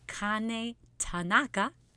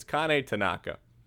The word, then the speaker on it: Kane Tanaka: Said it right. – You said it more right than usual. We do a lot of practice episodes of this podcast, and that's the most right. It's Kane Tanaka. You got scared in the middle. – I panicked. – She kept saying Tanaka, and I was like, it's not a Jewish festival of lights. It's a Japanese woman.